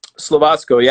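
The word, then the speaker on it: Slovácko: Absolutely. Slovako. – Slovasko. Yeah. It was very Slovasko. Close. You almost good pronunciation.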